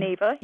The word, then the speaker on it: Neva: Is that how you pronounce her name? – Neva, yes. – N-E-V-A.